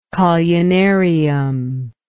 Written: collunarium

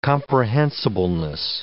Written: comprehensibleness

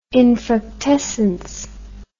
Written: infructescence

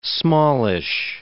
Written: smallish

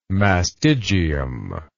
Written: mastigium

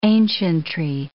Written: ancientry

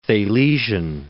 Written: Thalesian

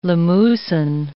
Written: Limousin

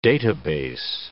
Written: database

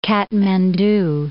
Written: Katmandu

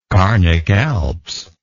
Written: carnic alps

